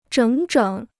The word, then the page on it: 整整: zhěng zhěng